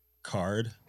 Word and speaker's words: CAURD: It's pronounced CARD. – Whatever, it's a CURD program, bro. C-A-U-R-D. – It is pronounced card, but it is curdling up like curd.